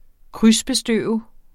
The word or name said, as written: krydsbestøve